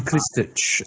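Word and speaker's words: Krstić: Ivan Kristic, I believe. – So that's the correct pronunciation.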